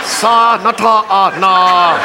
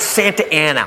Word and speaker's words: Santa Ana: Sa Nata Na. How? It's Santa Ana. What says Santa Ana? Look, I know I didn't finish school, but that frickin' says Sa Nata Na. They pronounce it Santa Ana.